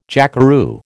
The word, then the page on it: jackeroo: American English